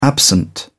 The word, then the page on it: absent: British English